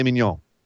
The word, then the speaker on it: mignon: Filet mignon. – Mignon. – Mignon. That's how it's pronounced.